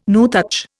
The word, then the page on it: nuthatch: Indian